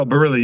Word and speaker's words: Barili: Is that the correct pronunciation or is it Barili? – No, Barili. You're right.